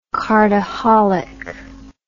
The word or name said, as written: cardaholic